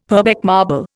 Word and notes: Purbeck marble: Indian

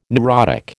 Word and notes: neurotic: American English

